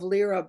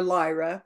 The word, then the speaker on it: Lyra: Oh, Christy, please describe the details of what you remember of coming into this game, starting with seeing the cradle of Lyra being created, or Lyra, it's pronounced both ways, the home base of the genetic and angelic human race.